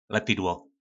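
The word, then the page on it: Lepidło: nářečí Po našimu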